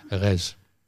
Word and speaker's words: Reims: Res. – Res. – Ja. – Res zit helemaal geen M in het woord. – Nee, dat spreek je niet uit.